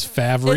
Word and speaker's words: Favre: Brett Favre, but his name is Favre. – It's Favre.